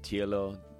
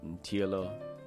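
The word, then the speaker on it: Ntyilo: After Sonny's Crib by Sonny Clark on Sonny's Mood, S-U-N-N-Y, Sonny's Mood. We listen to Ntielo, Ntielo, that is spelled N-T-Y-I-L-O, N-T-Y-I-L-O by Johnny Dayani from the Witch Doctor Sun album.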